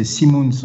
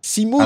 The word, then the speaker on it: Simoens: bonjour Patrick, presque, c'est Simons en fait. Simons ah, Damned, c'est pour ça, effectivement. Tu sais, moi, j'ai la, je connais le nom de famille, mais j'ai la, la prononciation à la française.